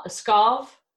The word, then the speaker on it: Pskov: Skov, P-S-K-O-V, Russia.